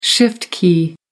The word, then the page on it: shift key: American English